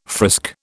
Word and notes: frisk: British English

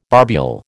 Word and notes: barbule: American English

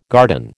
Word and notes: garden: American English

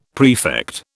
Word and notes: prefect: British English

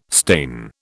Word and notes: stain: British English

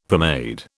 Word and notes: pomade: British English

